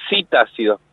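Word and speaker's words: psitácidos: Se escribe citácidos, P-S-I, citácidos.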